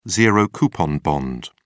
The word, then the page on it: zero-coupon bond: British English